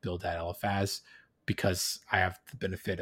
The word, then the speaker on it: Eliphaz: Eliphaz. Yeah. Like an elephant.